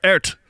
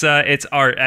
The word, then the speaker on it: Aert: Is it Art or Ert? – It's Art. I mean, that's how they say it on the broadcast all the time. So I mean, Wout Van Aert.